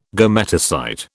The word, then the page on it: gametocyte: British English